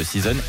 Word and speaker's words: SZNZ: Tout le monde remet Weezer, ils sont dans un délire depuis le début de l'année avec leur concept d'EP Season, ça s'écrit S-Z-N-Z, mais ça se prononce Season, et ça y est, on arrive à l'automne.